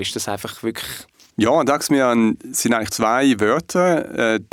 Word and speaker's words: Dagsmejan: Daxmian ist ein schwedischer Name.